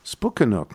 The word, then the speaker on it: Spooky Nook: Oh, spook rhymes with nook, so it's spook. Spook, Spooky Spookanook. – Spookanook, not with Duke.